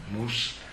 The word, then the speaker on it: Mauss: Tanto Durkheim, no? Mauss, francesi e pronunciati alla francese, no?